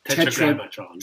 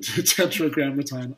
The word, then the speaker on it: Tetragrammaton: Tetragrammaton. – Tetragrammaton.